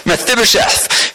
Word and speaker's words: Mephibosheth: And they find Mephibosheth, I think that's how you say his name. Yeah, got a thumbs up. Great. Mephibosheth, who is Jonathan's son, so the grandson of Saul.